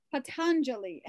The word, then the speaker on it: Patanjali: I always say his name wrong. I always add an extra N in there, I don't know why. Patanjali, anyway, which is much more beautiful than just making up a name.